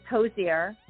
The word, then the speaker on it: Hozier: His name is Hozier, H-O-Z-I-E-R, Hozier.